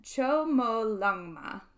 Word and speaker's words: Chomolungma: Chomolungma.